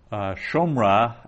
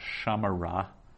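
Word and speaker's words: shomra (שמרה): Now that is shomra and not shamara. Shomra, it's a short O, for reasons that will continue to elude you until we get in the habit of reading accented text.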